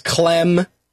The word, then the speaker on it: Clem: whose last name is Clem, rhymes with phlegm, touched her hairy clam.